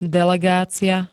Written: delegácia

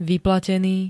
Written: vyplatený

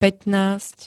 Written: pätnásť

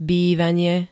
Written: bývanie

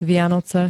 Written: Vianoce

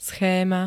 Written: schéma

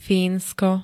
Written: Fínsko

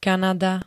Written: Kanada

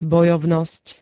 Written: bojovnosť

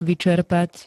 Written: vyčerpať

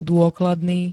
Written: dôkladný

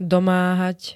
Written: domáhať

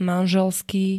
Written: manželský

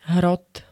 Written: hrot

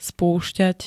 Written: spúšťať

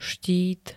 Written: štít

